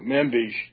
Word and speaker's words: Manbij: I say manbij. How do you say that? – Me too, manbij.